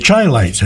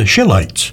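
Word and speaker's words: Chi-Lites: The Chilites, as they're called in the UK, the correct pronunciation is the Shilites, because they were from Chicago.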